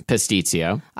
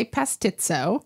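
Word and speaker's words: pastitsio: Pastizio. – I wanted to say pastizo. – Yeah, that's another way of saying it, an incorrect way.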